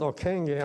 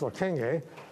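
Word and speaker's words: Ockenga: And we're going to talk about Harold, I think the name is pronounced Okenge.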